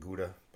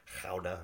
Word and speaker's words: Gouda: We pronounce it Gouda, but that's the Dutch cheese. I think the Dutch people say it's pronounced Gouda or something like that, whatever.